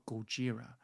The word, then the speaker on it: Gojira: And it just, this monster, Godzilla, or Gojira, as is pronounced in Japan, is like an act of God.